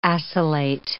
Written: acylate

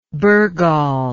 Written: bergall

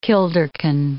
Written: kilderkin

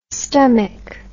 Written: stummick

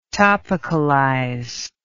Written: topicalize

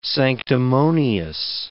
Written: sanctimonious

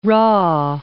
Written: rah

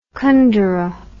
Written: Kundera

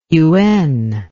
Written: yuen

